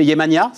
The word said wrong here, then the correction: Yemanja: ça, ça, se prononce à la. – Perdu, presque. Yemanja.